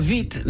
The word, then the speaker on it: Witt: Well, it was Katarina Witt, not Katarina Witt. – Yeah. – Well, because the W is pronounced like a V.